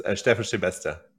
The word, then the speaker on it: Steffen Schebesta: Say your name for me, man. I want to make sure we get this right. I know I almost got it right. I know it's difficult. It's Steffen Schebesta.